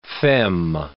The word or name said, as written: fem